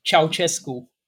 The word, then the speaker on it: Ceaușescu: Ceaușescu.